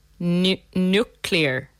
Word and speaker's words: nuclear: This is a word where people often get confused about the order of the sounds and pronounce it nuclear, which yeah. is what I say. – But remember to get the sounds in the correct order and pronounce it nucle- N U C, nuclear nuclear.